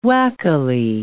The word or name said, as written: wackily